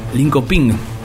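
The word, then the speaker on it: Linköping: el Lincoln Pong, eh, ¿cómo se dice? Lincoln Ping, producido por Jim Walker.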